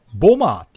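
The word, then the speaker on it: bomber: うん、これ英語発音すると、間にある B は発音しないんですよ。だから、ボマーって言うんですよね。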